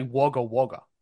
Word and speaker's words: Wagga Wagga: Wagga Wagga. – That is how you pronounce it with the American accent. I'll give you that. We would say Wagga Wagga.